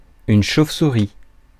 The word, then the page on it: chauvesouris: France; /ʃov.su.ʁi/